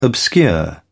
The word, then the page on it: obscure: British English